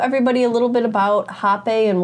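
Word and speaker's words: Rapé: So I know that it looks like it reads rape, but it... – Oddly enough, yes. – Oddly enough, but it is pronounced Hape, and so that you do see sometimes pronunciations or the spellings of it to be with an H, just to kind of have that distinction. – Yeah. – It does have the E with the line on the top, but for the purposes, it is pronounced Hape, and it is spelled R-A-P-E, you know? – Yeah.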